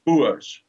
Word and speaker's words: Boer: And the citizens of these two republics call themselves Boers, or Boer, as we will pronounce it in Afrikaans.